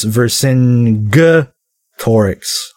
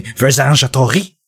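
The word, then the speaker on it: Vercingetorix: I have always heard it was pronounced Vercing Torix. Hard G. Who cares? No, I'm just kidding. It might be. I was just copying what my French teacher. She would say, Versingetorix. And that's how I, whenever I see that fucking word, because that's the first time I heard it.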